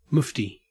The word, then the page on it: mufti: Australian English